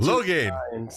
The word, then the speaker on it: Lohane: How do you say Lonan? – Come on, baby. You can do better than that. – No, 2 + 2. – Lohane. – So Lohane. Okay. – Brendan Lohane.